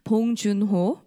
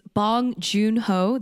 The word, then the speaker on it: Bong Joon-ho: And basically the director is I don't know how to say it in an English accent. So it's Bong Joon-ho. Okay. – Okay. – So it's Bong Joon-ho. There you go. That's how you say it in English accent.